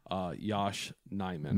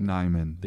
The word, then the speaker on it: Yosh Nijman: I even posted that clip where Jason Wilde asked him point blank, like, how do you say your name? Because even Rodgers was saying it wrong. And it is Yosh Nijman. – Nijman. – They don't have a J in Dutch, I believe is what he said.